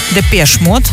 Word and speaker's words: Depeche Mode: И он как раз, он основатель группы Depeche Mode. Depeche Mode, Андрей, все время поправляет нас с этим ударением, но я уже научилась.